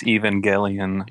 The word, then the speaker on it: Evangelion: Yes, Neon Genesis Evangelion.